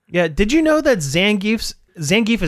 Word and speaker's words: Zangief: But it is like one of those popular like nomenclature kind of things where it works either way, but the official Capcom pronunciation is Zangief instead of Zangief.